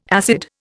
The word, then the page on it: acid: Indian